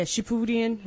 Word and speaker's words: Shippuden: Yeah, Shippuden, however mm-hmm. you pronounce that. – That's how you pronounce it.